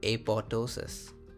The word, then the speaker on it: apoptosis: Oh, by the way, there's a subset of people who pronounce that apoptosis. – Apoptosis? – Yeah, they so a lot of people told me initially when I was learning this that the second P is silent, and what? – Now I don't even know what is right.